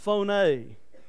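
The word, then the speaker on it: phoné (φωνή): It's pronounced phoné. When you look at it in the English spelling, it is P-H-O-N-E.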